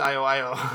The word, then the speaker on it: I.O.I.O.: Is it 10-10 or no, I-O-I-O. Yeah, mm-hmm. That's it. It was I-O-I-O.